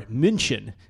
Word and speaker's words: München: But it's the third game overall in Germany as the Seahawks and Bucks played last November in Munich or München. As the locals say over there.